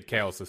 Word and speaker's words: Chaos: Is it officially like first name Chaos, last name Bender? Or am I saying that right? – When it comes to RuneScape, I would say Chaos is fine.